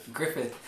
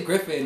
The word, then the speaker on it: Griffith: How do you say his name? – Griffith. – I want to say Griffin, and I'm like, nah, that's not it.